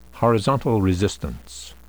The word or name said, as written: horizontal resistance